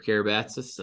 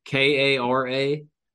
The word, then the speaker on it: Karabatsos: Listen, follow me on Twitter at Jacob Karabatsis. I'm going to spell that for you guys. You know how to spell Jacob, but my last name, good luck. Karabatsos.